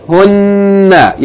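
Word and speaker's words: Hunna (هُنَّ): Hunna. – Short vowel, though. Short vowel. Hold the shadda on the noon, but the vowel is short. – Hunna. Yani it's a combination of hun and then na. Hunna.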